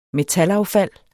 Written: metalaffald

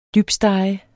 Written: dybstege

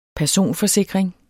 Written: personforsikring

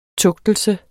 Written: tugtelse

